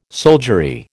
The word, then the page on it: soldiery: American English